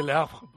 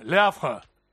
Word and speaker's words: Le Havre: Se dice Le Havre, porque la E final en francés no se pronuncia.